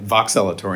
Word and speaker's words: Voxelotor: But you guys have had a very busy year with getting your rolling NDA submission through the FDA for Voxelotor. Am I saying that correctly? – You are saying it correctly. Voxelotor.